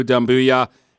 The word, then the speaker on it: Doumbouya: It's Booyah like Stuart Scott, but it's Ya instead of Boo that's emphasized. So good job on that, Dennis. – Thank you. – Sekou Doumbouya.